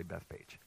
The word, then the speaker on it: Bethphage: And if you have a different pronunciation for that word, I looked it up a few years ago, and I think it's technically Bethphage, and that's just such a weird thing. I'm going to say Bethphage.